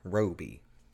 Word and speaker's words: Robie: I had to look up how to say his name because it's spelled like Robbie, but with only one B, and it didn't make sense in my head at all. But Derek Roby.